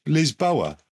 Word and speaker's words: Lisboa: And just in the same example that I gave, we call the capital of Portugal in English Lisbon, and in Portuguese it's pronounced Lisboa. So if they want to call it Kyiv in the Ukraine, that's fine.